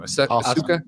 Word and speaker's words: Asuka: Asuka.